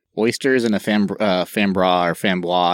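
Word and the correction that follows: framboise: I can never say it right. – Frambois.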